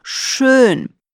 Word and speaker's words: schön: Обрати внимание на гласный в слове «schön». Его нужно произносить четко, потому что если сказать через «о», «шон», то это другое слово, которое означает «уже».